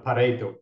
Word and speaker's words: Pareto: You have to say it correctly, right? Because it was named after an Italian, right? So it's the Pareto principle,